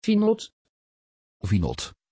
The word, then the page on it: vinod: Dutch